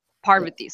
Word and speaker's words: Parvati: Oh, for everyone listening, you can see my pinned tweet, but it's Parvati. So the first syllable is the strong one.